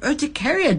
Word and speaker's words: Urticaria: Well, thank you for reaching out to us, you know, because I'd never heard of Urticaria Day. Is it how you say it? – That is correct, yes.